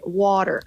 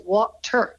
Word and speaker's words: water: Pues es que la, la palabra water, yo digo water porque las T's en Norteamérica es como una R, water. Pero si tú vas a Inglaterra y tal, dirías water, con la T, marcan ellos la T.